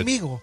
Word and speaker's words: conmigo: It's not soft. It's conmigo. – You don't want a soft G. – Yeah, you don't want that soft G there.